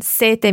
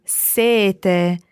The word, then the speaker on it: sete: It's very similar, but number seven is double T, sete, and sete meaning thirst is single T. So when you say thirst, you can have a very long E, sete, while when you say number seven, sete, you need to be very short on your E to allow a very long T sound, sete.